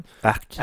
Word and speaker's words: Ark: Arc. Arc. Arc. Ça s'écrit A-R-K. donc, comme Arc.